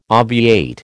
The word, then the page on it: obviate: American English